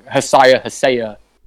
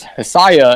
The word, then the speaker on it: Hasaya: I remember, I don't think I commented it, but Devin on one of his podcasts was calling Hasaya. – Yeah. – I was like, it's Hasaya, and I remember Thad specifically explaining it. But I had Googled it just to make sure. I like went to Google Translate, was like, let's see how they hear. And they're like, oh, it's Hasaya.